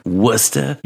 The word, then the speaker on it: Worcester: Worcester? – You're absolutely saying it right. It makes me proud. – Worcester, that's what it looks like. And they're like, it's Worcester.